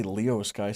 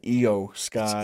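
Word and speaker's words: Iyo Sky: So her going against Io Sky, I want to say Leo Sky sometimes. I don't know why. Io Sky. – It's Io. – Io. E-I-E-I-E-I-O.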